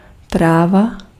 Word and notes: weed: /wiːd/

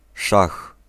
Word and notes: schaak: /sxaːk/

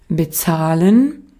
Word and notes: foot: /fʊt/; [fʊt]

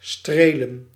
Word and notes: caresser: France; /ka.ʁɛ.se/

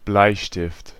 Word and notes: crayon: /kʁɛ.jɔ̃/

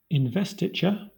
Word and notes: investiture: /ɪnˈvɛstɪt͡ʃə/; /ɪnˈvɛstɪt͡ʃɚ/

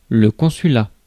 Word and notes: consulat: France; IPA: /kɔ̃.sy.la/

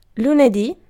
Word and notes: maanantai: [ˈmɑːnɑn̪t̪ɑi]; /ˈmɑː.nɑn.tɑi/

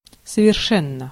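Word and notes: совершенно: /səvʲɪrˈʂɛnːə/; Russian

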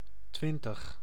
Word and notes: twintig: Dutch

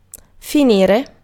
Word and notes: conclude: American English; IPA: /kən.ˈkluːd/

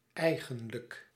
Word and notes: eigenlijk: /ˈɛi̯ɣə(n)lək/

